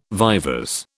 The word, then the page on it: vivers: British English